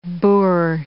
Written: boor

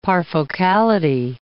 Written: parfocality

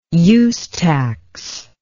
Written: use tax

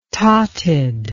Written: tauted

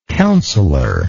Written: counselorship

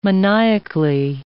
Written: maniacally

